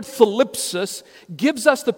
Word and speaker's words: thlipsis: The Greek word for tribulation is philipsis. Let me spell that for you. T-H-L-I-P-S-I-S. Philipsis. T-H-L-I-P-S-I-S.